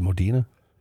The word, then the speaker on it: Modena: The 1985 Modena Spider, is it Modena? – Yeah.